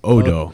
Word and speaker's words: Odo: I'm not saying Odo. I'm saying Odo. – Odo. – Odo. Frodo without the fro.